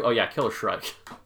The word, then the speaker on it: Shrike: So this is Killer Shriek, by the way, and I will say, or Shrike? – How do you say Shrike, that? Yeah. – Yeah, Killer Shrike.